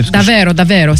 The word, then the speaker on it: Davvero Davvero: L'artiste s'appelle Artifive et le morceau, c'est Davvero Davvero. Est-ce que j'ai bien prononcé? – Davvero Davvero, si.